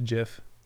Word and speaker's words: Jif: Sorry, I think it's pronounced Jif.